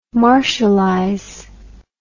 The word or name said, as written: martialize